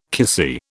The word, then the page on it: kissy: British English